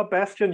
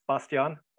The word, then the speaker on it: Bastian: Well, Bastian, yeah, it's the English pronunciation in Germany. Bastian.